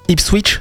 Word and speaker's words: Ipswich: Pendant que Fulham a perdu son match face à Ipswich. C'est comme ça, c'est bien prononcé. Ipswich Town.